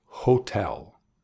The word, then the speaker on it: hotel: That's right, hotel, with the emphasis on the second syllable, hotel.